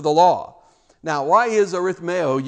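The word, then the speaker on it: Arithmeo (ἀριθμέω): Arithmeo, actually the emphasis is on the A.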